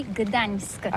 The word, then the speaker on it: Gdańsk: Oui, c'est pas très facile pour des étrangers, mais remarque bien que la langue polonaise, c'est la langue où on prononce chaque lettre qu'on écrit. C'est pourquoi on dit Gdańsk.